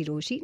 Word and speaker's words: Rochie: मेरा नाम है मिर्ची रोशी, नाम की स्पेलिंग है ROCHIE, and for the time being मेरे साथ है मेरे मिस्ट्री कॉलर.